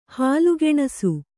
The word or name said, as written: ಹಾಲುಗೆಣಸು